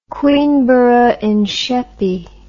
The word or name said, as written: Queenborough in Sheppey